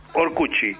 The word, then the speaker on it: Orkut: Orcuchi. Se pronuncia en portugués Orcuchi, como la termina en T. Esto es Orkut en español. O-R-K-U-T.